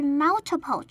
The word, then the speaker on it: multiple: 第二个可以注意的发音呢是 multiple 这个单字。这个单字分三个音节念，重音放在第一个音节 mul 上面。那 mul 的 u 发作嘴巴微张的 a 的音，后面的 ti 的 i 发作嘴巴微张的的音，整个单字念作 multiple。